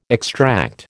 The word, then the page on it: extract: American English